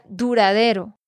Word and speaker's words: duradero: Nate, te quiero corregir la pronunciación de una de estas palabras es la palabra duradero.